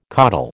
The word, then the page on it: caudal: American English